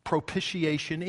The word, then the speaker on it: propitiation: But that's really, those are decent words, but they don't really capture the essence of what propitiation is. And while we're doing this, if you'll forgive me a moment of indulgence, I want to be sure you understand something. You pronounce it propitiation. It's not propitiation.